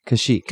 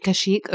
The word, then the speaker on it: Kashyyyk: How do you pronounce the Wookiee planet? – Kashyyyk. – Kashyyyk. Okay, yes.